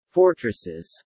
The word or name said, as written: fortresses